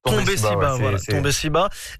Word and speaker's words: Tombé Siba: Alors Tombé Sida, est-ce que je le prononce bien? – Tombé Siba, qui arrive en 2020 et qui cumule plusieurs millions de vues sur les plateformes de stream.